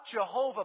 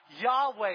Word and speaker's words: Yahweh: But virtually every Hebrew scholar today believes a better pronunciation of the name of God is not Jehovah, but what? Yahweh, exactly.